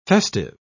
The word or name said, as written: festive